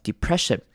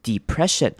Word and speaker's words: depression: depressiond epression 这个字它的 e 呢，就开头的那个 e 我们可以念成长母音 e 念成 depression，或者是如果你要省略的话，你可以念成轻音 depression depression，两种声音都可以。